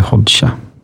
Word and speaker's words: Hoxha: Ja, stavas med X där, så att, men tydligen uttalas det Hoxha.